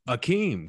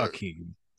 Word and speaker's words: Hakeem: And so he showed up and he was like, my name is Akeem, because he didn't say H's. And so they said, well, right. Akeem, that shit sounds like it's spelled A-K-E-E-M. And so that's right. How he was. And then finally, in his career with the Rockets, he had enough confidence. He was like, actually, y'all been spelling my name wrong. It is right. Hakeem. – It's Hakeem. Right.